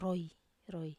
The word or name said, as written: roi